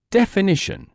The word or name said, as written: definition